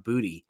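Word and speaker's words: Boutte: It also said it's Kayshon Booty.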